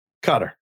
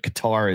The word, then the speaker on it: Qatar: Before we begin, is it Cutter or Qatar? – Cutter. – Cutter. That's official pronunciation? – That's the way you're supposed to say it. Qatar is, you want to, but Cutter is the way you're supposed to say it.